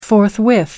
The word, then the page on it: forthwith: American English